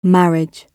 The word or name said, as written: marriage